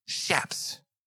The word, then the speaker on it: chaps: Chaps. Do you know that's how you pronounce it? It ain't chaps. It's chaps. No, don't look at me like that. I don't make the rules. Shaps.